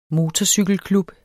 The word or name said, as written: MOTORCYKELKLUB